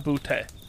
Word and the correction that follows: Boutte: and you're telling me I'm wrong. – That is what I'm telling you. It was confirmed after we made a whole scene about it last episode. – So the correct pronunciation you're going to tell me is Booty. – It is 100% Kayshon Booty.